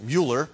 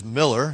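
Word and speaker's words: Müller: Sometimes you might be tempted to pronounce it Mueller, but it is Miller.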